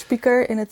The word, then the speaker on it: spijker: Dus spijker is speaker, zo spreek je dat uit in Twente.